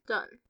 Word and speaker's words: Dhun: D-H-U-N? How would you pronounce that? Dune? – Say it again. – D-H-U-N. Dune. – Yes.